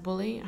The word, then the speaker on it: Bulley: Yeah, uh, so I, I, uh, I assume it's pronounced Bully. I haven't listened to anyone and the newscasters or anyone speak her name, but it's B U L L E Y.